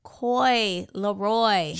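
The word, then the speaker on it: Coi Leray: Coy Leroy.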